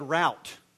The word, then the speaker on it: route: route. It's route. Okay, the dictionary definition pronunciation is R-O-O-T.